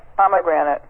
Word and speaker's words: pomegranate: But you're pronouncing it as palm granite? – Pomegranate. – Yeah, that's the way I should say it, I'm sure. I just have always said plum granite, kind of.